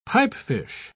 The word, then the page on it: pipefish: British English